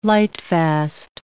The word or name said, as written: lightfast